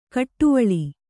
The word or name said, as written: ಕುಡಿವಳ್ಳೆ